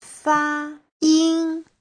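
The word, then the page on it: fayin: fa1yin1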